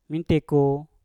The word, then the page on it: minteku: Mpur